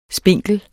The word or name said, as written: spinkel